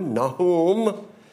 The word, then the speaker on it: Nahum: We are in the book of Nahum, or as it is pronounced in Hebrew, Nahum.